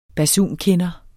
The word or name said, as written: basunkinder